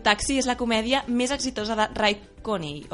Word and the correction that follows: Cooney: o Cooney. – Cooney.